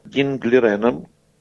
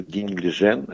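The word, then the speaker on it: Дин Лижэнь: Матч пройдет за звание чемпиона мира между Яном Непомнящим, как уже было сказано, и вторым шахматистом мира Динглиреном, или Динглижен, это разное произношение, из э, Поднебесной, из Китая.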